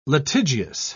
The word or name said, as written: litigious